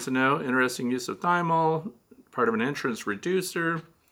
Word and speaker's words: Thymol: It's spelled Thymol, T-H-Y-M-O-L, but we're supposed to say Thymol because it's thyme oil.